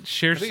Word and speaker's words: scherzo: Is that how you pronounce it? Scherzo? – Scherzo. – Scherzo? – Yeah, because, you know, he had the scherzo for motorcycle and orchestra as well.